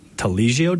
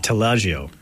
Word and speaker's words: Taleggio: But they've got the Drawing Room has a, am I saying this right? Taleggio dip? – Taleggio. Close.